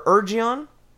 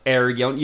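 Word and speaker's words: Eregion: 19 of these rings were outsourced to the elven smiths of Aragon or Urgion. I haven't spoken this out loud. – Ergion.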